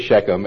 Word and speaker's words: Shechem: it's spelled in the King James S-I-C-H-E-M, but it's really Shechem, S-H-E-C-H-E-M.